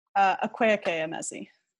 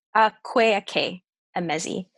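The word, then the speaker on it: Akwaeke Emezi: Akweke Emezi. – Akweke Emezi.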